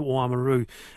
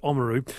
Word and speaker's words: Oamaru: He said he went to Oamaru and almost every single local he came across pronounced it Oamaru.